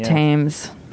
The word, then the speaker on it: Thames: Oh, God, how do you say that? Tom, the river, T-H-A-M-E-S. – I think it's the Thames. – Thames.